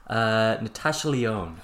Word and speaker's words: Natasha Lyonne: Well, Poker Face, the TV show, stars, I cannot pronounce her name, Natasha Lyonne? – Natasha Lyonne.